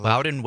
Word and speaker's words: Loudon: I don't know how you say L-O-U-D-O-N. Loudon? Loudon? Yeah. Uh-huh. Loudon Wainwright III.